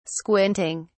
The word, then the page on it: squinting: British English